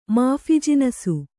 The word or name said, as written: ಮಾಫಿಜಿನಸು